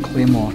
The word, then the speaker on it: Claymore: And the lyrics are interesting, and, you know, people ask me, what's a clay, it's spelt like Claydamore, but it's actually Claymore.